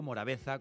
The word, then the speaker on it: Morabeza: Pronunciado Morabeza con Z escrito.